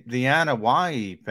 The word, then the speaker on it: Anoa'i: The Anoa'i family, which is the famous wrestling family. That's how you pronounce it, Anoa'i, and it's spelled A-N-O-A-I, I believe.